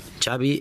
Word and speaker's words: Xavi: Xavi Hernández. – What is the correct pronunciation of your first and last name? – Xavi Hernández. – Xavi. Okay. – Yeah, Xavi like chocolate.